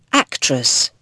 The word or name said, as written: actress